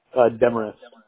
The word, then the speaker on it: Demarest: Demarest. – Could you spell that? – D-E-M-A-R-E-S-T. – E-S-T, okay.